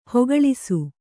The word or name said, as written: ಹೆಕ್ಕಳಿಸು